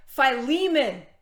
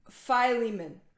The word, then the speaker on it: Philemon: Philemon. I think I'm going to call this, pronounce phi like Thai. Philemon.